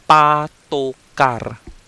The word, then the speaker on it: patukar: patukar